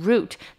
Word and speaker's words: route: 觉。那我们可以说 I think he's being too aggressive about this position。我想他对于这个职缺人有点太过于积极了，也就是说他好像有点图谋不轨的感觉。那再来呢，我们要来看的是 route。Route 呢是路线的意思，它在美国呢也是指某一条公路，像我们最有名什么 Route 66，就是用这一个字。那要记得呢，它的 OU 呢是发 u 的音 root，那 e 不发音。那我们可以说 In Robert Frost's poems he has taken the route less traveled by，在 Robert Frost 的诗中他选了一个比较人烟稀少的路径。那跟这个路径或路线比较相关的呢就是捷径。那捷径的英文呢则是 shortcut，就是一个短的然后切过去的感觉，就是 shortcut。